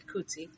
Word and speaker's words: Coetzee: Coetzee, which is spelled C-O-A-T-Z-E, but I looked it up and you pronounce it Coetzee.